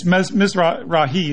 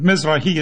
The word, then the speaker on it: Mizrahi: And he can help, I think, give us, that's Mizrahi is how they pronounce it. There's different pronunciations, but Mizrahi is how they pronounce this element of Jews, which are definitely not Ashkenazi.